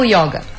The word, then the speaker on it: yoga: Muitas pessoas ainda pronunciam yoga, certo? Mas a pronúncia certa dentro do sânscrito, que é a língua... É uma língua morta, né? Ah. Da Índia, digamos assim.